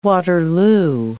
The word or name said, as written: Waterloo